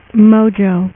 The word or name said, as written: Mojo